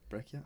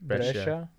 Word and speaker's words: Brescia: Brecchia? How do you say that? – Brescia.